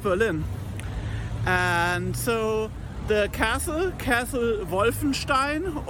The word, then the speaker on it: Wolfenstein: And I just want to tell you how to pronounce Wolfenstein correctly. It is a very German-sounding word for sure.